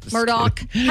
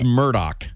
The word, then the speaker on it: Murdaugh: Murdaugh. How do you say his name? – I think it's Murdaugh.